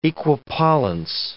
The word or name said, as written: equipollence